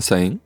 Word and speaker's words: Sahin: Aujourd'hui, on est en présence de Kevin Sain, si je le dis bien, parce que tu peux me reprendre si ce n'est pas le cas. – Sain, ouais, tu l'as dit, je crois.